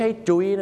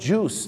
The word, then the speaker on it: juice: ไม่ใช่จุ้ยนะฮะต้องออกเสียงว่า juice นั่นเองครับ